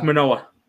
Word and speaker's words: Manoa: They have Moana, what, Monana? I don't even know how to pronounce his name. – Alec Manoa. – Manoa coming up for tomorrow's game.